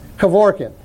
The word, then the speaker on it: Kevorkian: How do you say his name? Kevorkian? The suicide doctor? Kevorkian.